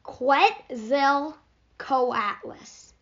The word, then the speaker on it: Quetzalcoatlus: Quetzalcoatlus.